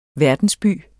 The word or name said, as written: verdensby